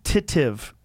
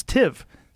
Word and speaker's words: substantive: But it's not titive. It's tiv. Substantive.